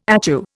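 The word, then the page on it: étui: Indian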